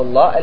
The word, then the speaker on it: الله: عندهم أنها لام شمسية، اللام الشمسية، فهي مضغمة في الثانية والثانية مشددة، فأنت تنطق بالثانية، هذه الثانية المفخمة فتكون مشددة في نفس الوقت، فخمة مشددة، وتكتب ماذا؟ بلامين، لأن أول الكلمة لام ودخلت عليها ال، ولهذا كتبت ماذا؟ بلامين. وأصل الله الإله، هي أصلها هكذا في الاشتقاق، ثم دخلت عليها اللام فحذفت ماذا؟